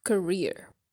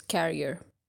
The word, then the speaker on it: career: So that is career, C-A-R-E-E-R, career. We generally pronounce it as carrier, but that's actually very, very different. Carrier is C-A-R-R-I-E-R.